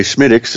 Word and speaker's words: Smithwick's: It says a Smith, I say Schmidt's is how you pronounce it, right? But it's spelled Smithwick's.